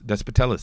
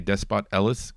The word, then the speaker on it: Despotellis: Number four is going to be Despotellis. Sorry, I always want to say Despot Ellis because that's how I remembered how to spell it, but it's Despotellis.